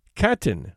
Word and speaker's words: Katyn: Katyn, which is pronounced as written, is a town about 15 miles west of Smolensk.